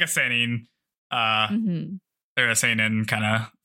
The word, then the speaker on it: seinen: Seinen.